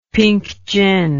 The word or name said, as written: pink gin